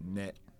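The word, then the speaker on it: ne (ね): It's pronounced ne, and it means like, oh, doesn't it?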